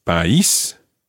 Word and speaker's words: país: For example, with país, we've got the accent on the I. So it's país. If it were on the A, it would be país.